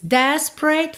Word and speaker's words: desperate: Desperate. Perceba como temos duas letras E que não são pronunciadas, tá? Não é desperate, tá? Desperate. Pensa em spray, ok? Que do P vai direto pro R.